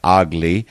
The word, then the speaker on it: AGLI: That's African Great Lakes Initiative. It's pronounced AGLI.